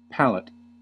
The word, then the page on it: palette: American English